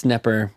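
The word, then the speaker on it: Knepper: Yeah, it's Nepper with a K.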